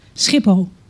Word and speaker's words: Schiphol: Okay, properly in Dutch, how would I say that? – Schiphol. – Yeah, and Rotterdam? – Rotterdam.